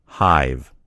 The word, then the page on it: hive: American English